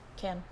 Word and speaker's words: Cannes: Cannes. Can. There's no, you don't say the S. I thought it was ES. – Yes, it's spelled that way, but you don't say the ES.